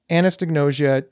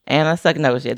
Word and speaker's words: anosognosia: We have done such a great job of bringing anosognosia to the forefront of getting, did I pronounce it wrong? – Anosognosia. There's no T.